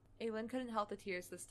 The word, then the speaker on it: Aelin: I forgot how to say her Aelin. – Name. Aelin. – It's like the letter A and yeah. Then Lin. Yeah. He took Aelin.